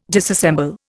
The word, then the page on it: disassemble: Indian